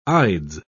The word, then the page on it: ides: British English